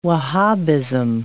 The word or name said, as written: Wahhabism